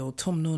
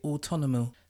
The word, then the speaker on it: autumnal: It's got very autumnal now. I couldn't say that word the other week. My friends had to break it down into syllables. Can you imagine that? Autumnal. I used to say autonomal.